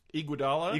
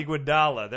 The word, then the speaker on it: Iguodala: Iguodala. – Iguodala, there we go.